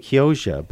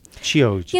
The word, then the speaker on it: Chioggia: So I'm saying it wrong. I'm saying Chioggia, but it's Chioggia.